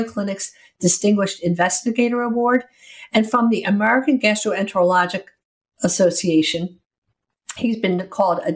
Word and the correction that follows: gastroenterological: I should be able to pronounce gastroenterological. I left off the last syllable.